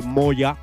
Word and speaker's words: Mojah: Así que bueno, allá vamos con el último tema de la tarde, Last Tune, este tema de Ethnic de Moya. El tema llamado Ethnic de Moya. Y bueno, el tema como tal se escribe M-O-J-A-H, el nombre del artista, como no digo Moya.